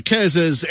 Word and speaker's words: Kerzers: What do we know about, is it Kerzers? Is that how I pronounce it? – Yeah, Kerzers.